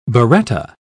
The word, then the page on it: biretta: British English